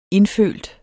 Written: indfølt

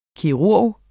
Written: kirurg

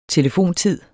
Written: telefontid

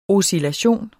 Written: oscillation

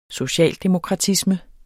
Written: socialdemokratisme

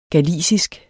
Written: galicisk

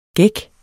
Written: gæk

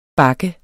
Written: bakke

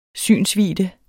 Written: synsvidde

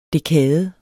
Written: dekade

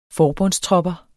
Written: forbundstropper

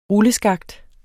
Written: rulleskakt

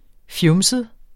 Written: fjumset

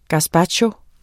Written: gazpacho

